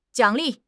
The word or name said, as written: 奖励